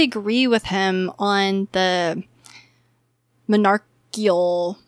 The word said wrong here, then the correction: monarchical: help me say that. How do you say that? Monarchial. Say it for me, Chuck. – Monarchial. – Well, that presentation. On a personal level. – Monarchical. Monarchical. – Monarchical. Monarchical. Okay.